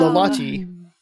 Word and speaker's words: Lolotte: Not that I really gave it much thought, but because they sang about Little Lottie, I just thought Lolotte.